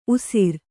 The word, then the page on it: ಉಸ್ರ: usra; Kannada